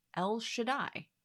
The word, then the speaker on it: El Shaddai: But to pronounce this in Hebrew, you can say El Shaddai.